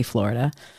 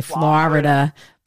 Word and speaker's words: Florida: I say it right, I think. I used to say Florida, but I don't say that anymore. I had a roommate that was from Florida, though. So she was like, it's 2 syllables and not 3. And that was kind of the way she put it to me.